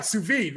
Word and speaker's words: sous vide: sous vide, right? – Yeah, yeah, oh, absolutely.